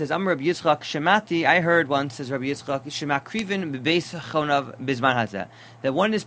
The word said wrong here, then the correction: Beis Chonio: Beis Chonio, it's pronounced? Okay, so Beis Chonio.